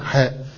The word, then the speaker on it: حاء: قال الإمام مسلم حدثنا محمد بن عبد الله بن نمير قال حدثنا أبي يعني عبد الله ابن نمير حاء، وحاء تنطق هكذا حاء، وهي حاء التحويري وفيها كلام، لكن هي يعني الجاري على العادة وهو الأظهر إن شاء الله أنها حاء التحويل، يعني تحويل من إسناد إلى آخر، فتنطق حاء مهملة حاء.